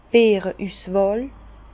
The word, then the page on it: d' Beere Üswàhl: Bas-Rhin; Herrlisheim